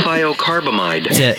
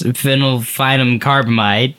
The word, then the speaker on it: phenylthiocarbamide: Phenylthiocarbamide. – To help us pronounce phenylphenomcarbamide.